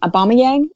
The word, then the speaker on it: Aubameyang: Well, I think it's going to be a combination of both, because and I'm going to butcher his name, Aubameyang. Is that how you say his name? – Yeah.